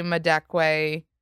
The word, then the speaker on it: Madekwe: You actually just went to a Vanity Fair party and called Archie Madekwe.